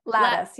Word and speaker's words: Latas: Latas. Yep. – Latas. Just like the fence.